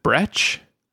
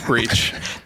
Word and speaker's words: Breach: Is it Brech? it's breach. That's the American pronunciation.